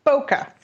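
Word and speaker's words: Boca: Can you pronounce that for me? Bokeh? – Bokeh. Just Bokeh. – Plain old Bokeh. – Like the Florida town. Bokeh.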